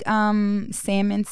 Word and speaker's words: salmon: Do you like salmon sandwiches? – Look at you saying it right. – I know. I had. It's not salmon.